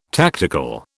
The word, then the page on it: tactical: British English